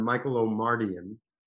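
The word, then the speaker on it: Michael Omartian: So in terms of the Michael and Jermaine song, that was the record producer and songwriter, Michael O'Mardian is how you pronounce his name, brilliant man, asked me to get involved and write that song.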